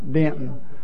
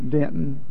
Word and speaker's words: Denton: Yeah, because I didn't say it right. Denton. Yeah, Denton. The University of North Texas State University.